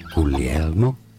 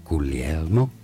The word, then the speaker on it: Guglielmo: Guglielmo Marconi. Is how it's said in Italian. Guglielmo. – You said Guillermo.